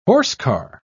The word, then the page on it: horsecar: British English